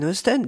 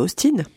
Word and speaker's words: Austen: Ça fait une dizaine d'années que j'avais envie d'écrire autour de Jane Austen. D'ailleurs, c'est chouette que vous disiez Austen et pas Austin, parce que ça s'écrit avec un E.